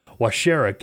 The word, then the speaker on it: Waushara: Uh, today, August the 12th, and I'll, I'll, if I butcher this, sorry, Washer, war, wa, wa, wa I can't even pronounce it. Washera County, maybe. W A U S H A R A.